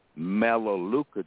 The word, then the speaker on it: Melaleuca: Let me spell it for you. M-E-L-A, Mela, M-E-L-A-L-E-U-C-A. It's pronounced Melaleuca.